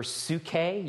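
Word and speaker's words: psuche (ψυχή): May your whole spirit, your pneuma, soul, your psuche, you pronounce the Y like a U there, and body, soma, be preserved blameless at the coming of our Lord Jesus Christ.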